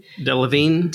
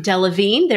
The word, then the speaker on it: Delevingne: Uh, James Corden, Cindy Crawford, Cara don't know how to say her net last name. – Delevingne. – Delevingne.